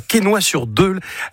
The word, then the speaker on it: Quesnoy-sur-Deule: Tu habites à Quesnoy-sur-Deule. Est-ce que je l'ai bien prononcé ? Je n'ai pas écorché le nom. – Impeccable.